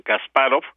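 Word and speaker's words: Kasparov: Sí, lo dije bien, Gasparov y los tres mejores jugadores latinoamericanos,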